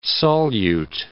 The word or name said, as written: solute